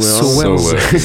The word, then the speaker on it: Sowers: Sowers. Je sais qu'il y a souvent des petites mauvaises prononciations. Sowers, S-O-W-E-R-S.